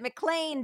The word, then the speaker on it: McLean: And I'm really glad I watched it because it's spelled, looks like McLean, like L-E-A-N. Uh-huh. But they pronounce it McLean, just like John McLean.